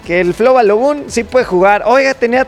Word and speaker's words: Flo Balogun: Con el tema de Flo Balogun.